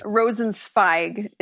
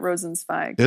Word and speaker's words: Rosenzweig: Rosenzweig is how I've heard it. Rosenzweig.